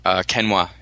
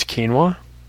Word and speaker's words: quinoa: Chop up some chicken with some quinoa, throw in some spices, some... – Did you mean to pronounce quinoa? – Quinoa. Dude, I'm from New York. Quinoa. – Quinoa? – Quinoa. Keen.